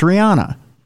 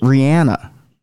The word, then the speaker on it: Rihanna: It's Rihanna. – Yeah. – But she pronounces it Rihanna. – Oh, she does. – She does. That's what she claims the real pronunciation of her name is. Rihanna.